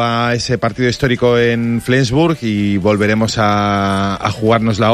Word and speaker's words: Flensburg: Flensburg. ¿Lo digo bien? – Sí. – Flensburg alemán.